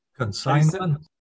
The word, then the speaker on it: consignment: They're taking out an N, and certainly the silent G in consignment is missing in the transliteration.